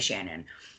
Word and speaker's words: Shannan: The most I've heard has been Shannan, so I'm going to go with Shannan because it's spelled... – Okay, just put an and on mix. – Yeah, pretty much. I'm hoping. If I'm butchering it to the family, I'm so sorry. I'm just trying to... go by association of what I've heard.